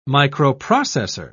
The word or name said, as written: microprocessor